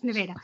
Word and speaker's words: Nevada: Because we can't say that right either. It's Nevada. It's Nevada.